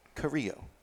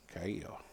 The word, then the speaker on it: Carrillo: It's Carrillo. Carrillo. Carrillo. You can't put the double L Y sound with the R's.